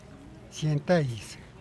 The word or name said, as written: cien taiz